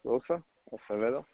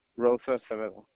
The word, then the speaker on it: Rosa Acevedo: My name's Omar Rosa Acevedo. – Omar, and what's the last name? – Rosa Acevedo. – Can you spell that? – R-O-S-A-A-C-E-V-E-D-O. – Okay, sir. It's R-O-S-A-C-E.